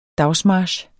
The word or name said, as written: dagsmarch